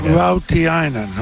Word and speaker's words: Rautiainen: But the kids, Harkness, Peltier, O'Reilly, Rautalainen. – Rautiainen. – Okay. So you don't pronounce the L. Is there an L? – No. – There's no L. It's just my eyes.